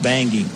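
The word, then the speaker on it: Bange: She spells her last name B-A-N-G-E. B-A-N-G-E, pronounced Bangi.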